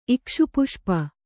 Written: ಇಕ್ಷುಪುಷ್ಪ

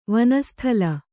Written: ವನಸ್ಥಲ

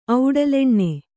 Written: ಔಡಲೆಣ್ಣೆ

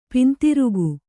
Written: ಪಿಂತಿರುಗು

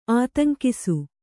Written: ಆತಂಕಿಸು

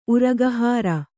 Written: ಉರಗಹಾರ

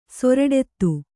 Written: ಸೊರಡೆತ್ತು

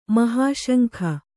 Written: ಮಹಾಶಂಖ

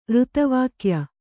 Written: ಋತವಾಕ್ಯ

